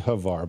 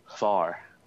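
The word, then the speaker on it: Hvar: I guess if you really say it right, it's called Var. You kind of, it should be one syllable. It shouldn't be Havar, but it's hard to say the way they do it, this HV sound together, like Vala.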